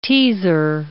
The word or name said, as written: teaser